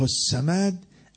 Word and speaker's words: الصمد: الله الصمد الف لام توی الصمد در کتابت آمده، در تلفظ نمیاد.